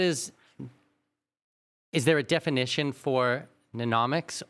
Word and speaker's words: mnemonics: So, okay, so the things that you've been describing so far, is this mnemonics? How do you say this? – Mnemonics. – Yeah. I have it written down here. It starts with an M.